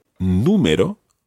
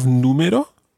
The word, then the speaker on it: número: Can you tell me anything about the spelling of número? – There's an accent on the U. – Yeah, because otherwise it would be número. – Número, which is wrong again.